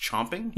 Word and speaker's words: champing: So, did you know that chomping is spelled with an A if it's like the phrase chomping at a bit, like you're a horse? – Is it still pronounced chomping? – Yep. – And it's not champing? – Mm-mm. It's C-H-A-M-P-I-N-G.